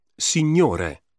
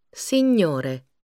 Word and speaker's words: signore: The combination GN is pronounced gn. Signore. Ingegnere. – Signore.